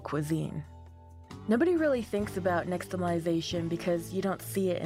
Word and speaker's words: Nixtamalization: Nixtamalization.